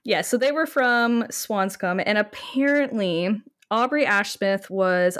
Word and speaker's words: Swanscombe: The Ashsmith family apparently resided in Swanscombe. Is that how you pronounce it? – Swanscombe. – Okay. – Yeah, so pretty much the way you did it.